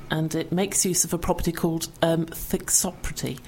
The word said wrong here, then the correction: thixotropic: I know, it was wrong. The myofascia is thixotropic, which means when you apply warmth and pressure, which comes from our hands, it actually changes state and it becomes more fluid.